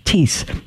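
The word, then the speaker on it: Thies: It's pre-recorded, but the first one we did is available, and Paul Teese, his last name is spelled T-H-I-E-S, T-H-I-E-S, but it's pronounced like peace, except with a T, Teese. The H is not pronounced.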